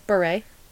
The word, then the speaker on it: Bure: Burrett. How do you say her name? – Bure.